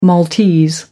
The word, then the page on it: Maltese: American English